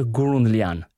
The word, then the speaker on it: Gourounlian: I'm going to say in French, Arthur Gourounlian.